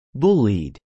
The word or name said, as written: bullied